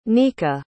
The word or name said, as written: neka